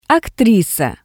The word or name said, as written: aktrisa